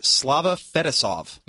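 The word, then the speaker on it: Slava Fetisov: It talks about a player, I may butcher this name, Slava Fedosov. – Nope, that's it.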